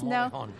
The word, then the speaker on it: Morejon: Morejon.